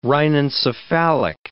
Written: rhinencephalic

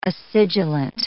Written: acidulent

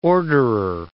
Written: orderer

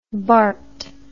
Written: barthes

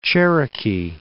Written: Cherokee Outlet

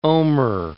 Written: omer

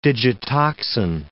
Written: digitoxin

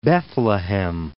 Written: Bethlehem

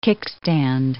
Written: kickstand

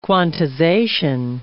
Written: quantization

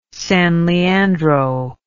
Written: San Leandro

